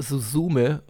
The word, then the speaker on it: Suzume: Der fragt zum einen, sofern bereits gesehen, wie fandet ihr Susume? Das ist ja der neue Film von Makoto Shinkai. – Ja, ich glaube, es wird Suzume ausgesprochen. Also Z ist immer ein sehr weicher Laut.